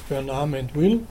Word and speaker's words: Mandeville: Bernard Mandeville, auf Englisch ausgesprochen, der kurz nach 1800 eine sogenannte Bienenfarbe äh, geschrieben hat, kurz, kurz nach 1700, Entschuldigung, also eben in dieser Zeit der Theodizee.